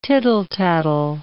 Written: tittle-tattle

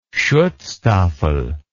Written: schutzstaffel

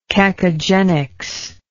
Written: cacogenics

